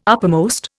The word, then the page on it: uppermost: Indian